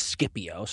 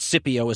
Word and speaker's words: Scipio: And by the way, whether you pronounce that Scipio or Scipio is sort of a matter of taste. The hard C, soft C sound question for Latin and Roman words is a long-running one. I tend to err on the side of what it would have sounded like if the Romans actually pronounced it, and they would have used a hard sound like Scipio, so that's where I go.